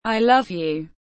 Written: i love you

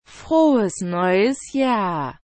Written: frohes neues jahr

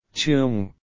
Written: te amo

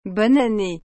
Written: bonne annee

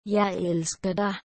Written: jeg elsker dig

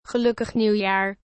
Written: gelukkig nieuwjaar

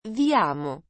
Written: vi amo

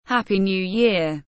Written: happy new year

